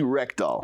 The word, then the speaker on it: Rekdal: Paisley Rekdal.